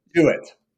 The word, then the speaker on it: Jewett: But Canadian Tamara how are we pronouncing her last name? Jewett? – Jewett. Tamara Jewett.